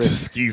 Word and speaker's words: Tuskegee: But what do you think about people who keep bringing up the Tuskegee? I can't say it right. – Tuskegee.